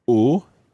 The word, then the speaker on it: aux: In that situation, it becomes O, but that's spelled A-U-X because it's plural. So to say I have sore feet, that's j'ai mal aux pieds. – J'ai mal aux pieds. – J'ai mal au pied. Très bien. So the O in that one sounds like O of the masculine singular, but it's actually spelled A-U-X. It's got a silent X on the end.